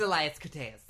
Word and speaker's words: Elias Koteas: It's Elias Koteas. – Is it? – Yeah. – Okay. – Always a pleasure, Elias Koteas.